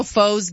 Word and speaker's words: faux: It was zero foes given. But you yeah. – know, it's spelled F-A-U-X.